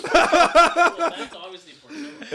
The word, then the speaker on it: Portillo's: Portillo's. – Portillo's.